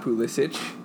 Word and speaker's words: Pulisic: Hey, he pronounces it the American way. It's not Pulisic. That's the Croatian way. – It's Pulisic.